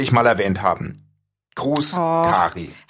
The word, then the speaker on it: Kary: K-A-R-Y, wie würdest du den Namen aussprechen? Kari oder Carrie? – Kari.